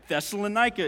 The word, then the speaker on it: Thessalonica: Thessalonica is right here.